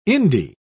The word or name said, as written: indie